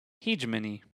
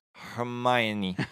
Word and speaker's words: hegemony: H-E-G-E-M-O-N-Y. Hegemony, hegemony, hegemony. Hegemony? – I think it's pronounced Hermione. Hermione. – Hermione. Yeah, how many people said Hermione for the longest time went, oh. – Okay. – Hegemony. Hegemony. – There's no R. Hegemony.